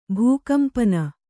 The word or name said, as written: ಭೂಕಂಪನ